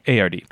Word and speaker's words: Cotard: How do you spell it? Cotard. – Oh, okay. That's how it sounds. Okay. That's because I'm pronouncing it as close to the letters as I can. – It's pretty phonetic.